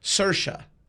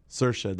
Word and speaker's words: Saoirse: It's, and I have the correct way to pronounce her name. Saoirse. – Saoirse, there you go. – Saoirse, which is, it's a Gaelic name. It's an Irish name.